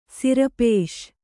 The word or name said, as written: ಸಿರಪೇಷ್